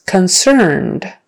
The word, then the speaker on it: concerned: Okay, the next word is concerned. Concerned. Accent is on the second syllable, but make sure you get that vowel in the first syllable.